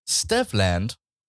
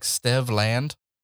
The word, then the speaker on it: Stevland: But Stevland Hardaway Judkins is how I believe it's pronounced. – Oh. – Stevland. Is his first name like Stedvin? – Like Stevland? Yeah.